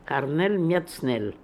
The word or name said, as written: Harnelmiatznel